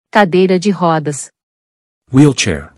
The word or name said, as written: Cadeira de rodas